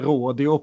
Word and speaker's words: rodeo: Han är en rodeo, Rodeoman. – Ja, hur uttalar vi detta egentligen? – enligt minnen från High Chaparral så heter det rådio på svenska.